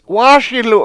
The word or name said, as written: wA7Silew